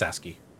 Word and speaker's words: ASCII: Is that pronounced ASCII? – Yeah, it's ASCII.